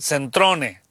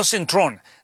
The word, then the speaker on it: Centrone: Demian Centrone. Así como lo escucho, lo estoy deletreando. No Centrón. Demian, con acento en la I, si la quieren poner a usted.